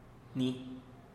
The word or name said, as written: ni